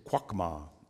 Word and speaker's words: chokma: So the Hebrew word for wisdom here is chokma. It's spelled C-H-O-K-M-A.